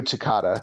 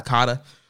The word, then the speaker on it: Takada: So I did watch the Battle Formation matchup there with Hashimoto going for the IWGP title against, was it Takeda? Is that how you say his name? – Nobuhiko Takada. – Takada. Okay, so yeah, I did watch that match, and it was a very good match.